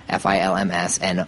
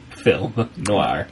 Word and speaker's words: film noir: Right, and I had mentioned that I had studied French and that I thought that the correct pronunciation was film noir, FILMSNOIRS. And it turns out that I was right. So, but in French, neither S is pronounced.